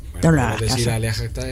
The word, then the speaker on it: alea iacta est: La pronunciación correcta es alea iacta est. Más o menos. Luego podemos discutir porque hay versiones.